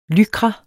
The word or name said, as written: lycra®